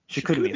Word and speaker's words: Chicoutimi: Chicoutimi, there you go, Quebec. – Oh, my goodness. Chicoutimi. – What? – Yeah. You've never heard of Chicoutimi? – No.